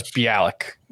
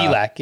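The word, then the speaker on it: Bielak: I would take him over any of the lively Hendricks or Bielik. Is that how you pronounce it? I was Belak. I always thought it was Bialik. Uh, Belak, yeah. – Belak.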